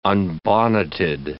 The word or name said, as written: unbonneted